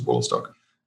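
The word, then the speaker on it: Wolastoq: There's like a campaign on now to go back to the original native pronunciation, the native name for it, which is Wollastock.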